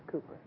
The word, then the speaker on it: Cowper: Looks like Cowper. Don't ask me why the English won't pronounce their own alphabet. Cooper, right? William Cooper.